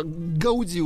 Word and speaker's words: Гауди: Э, Гауди удали, ударение на последнюю букву, угу.